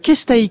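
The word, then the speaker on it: Keszthely: c'est à Kestei que nous allons, j'imagine que je le prononce n'importe comment. – C'est très bien.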